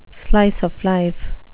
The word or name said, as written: slice-of-life